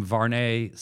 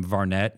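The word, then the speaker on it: Vuarnet: Like, you know, I remember like I had some Varnay sunglasses back in the day and I called them Varnet and people were like, no, it's this, whatever. And then when I learned how to pronounce it properly,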